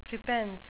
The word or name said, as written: prepense